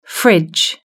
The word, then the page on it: fridge: British English